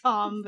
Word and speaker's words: Thom: Thom. – It's Tom, but yeah. – It's spelled Thom.